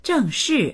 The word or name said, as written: zheng4shi4